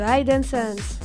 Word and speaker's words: Hide and Sense: de Hide and Sense. – Yes. Tu le dis bien, elle prononce l'anglais comme moi, enfin un peu mieux, presque.